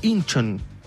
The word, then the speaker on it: Incheon: Se pronuncia Incheon.